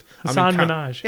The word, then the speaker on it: Hasan Minhaj: I'm just Hasan Minhaj.